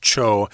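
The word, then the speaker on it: 町: that's pronounced cho in Japanese.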